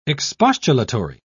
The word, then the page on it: expostulatory: British English